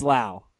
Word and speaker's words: Lowe: talking about Brandon Lau, Heath keeps talking about Brandon Lowe. Like you got to step in there and tell him it's Lau.